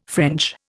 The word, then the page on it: French: Indian